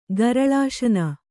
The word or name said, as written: ಗರಳಾಶನ